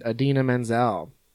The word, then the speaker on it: Idina Menzel: Film stars The Sandman, Adam Sandler, Lakeith Stanfield, Kevin Garnett, and Idina Menzel.